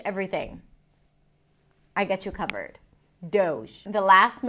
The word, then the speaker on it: Doge: This is an internet meme from 2013, and the way you pronounce it is Doge. Doge. It's a D sound, O as in go, and then the zh sound, as in vision. Zh. Doge.